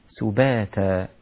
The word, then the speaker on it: سباتا: نومكم كلها رقيقة، وانتبه إلى الكاف المضمومة لا تفخم منك، لا تقول نومكم، نومكم سباتا. السين والباء رقيقة، والسين من حروف الهمس، سباتا هكذا. أيضا السين مضمومة، بعض الناس إذا نطق في السين ينطق فيها شيء من الشين، سباتا، سو سو، هذا خطأ، هذه ليست سين، سو سباتا، مثل أن تنطق بسم الله، سباتا.